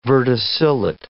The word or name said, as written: verticillate